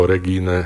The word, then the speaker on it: Regine: Bola to Dánka, teda dánske meno, to je, neviem po dánsky, ale počul som to vysloviť ako Regine, a Olsen sa volala teda.